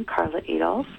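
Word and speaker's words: Karla Adolphe: Carla Adolph, and my name is spelled with a K and P-H-E on the end.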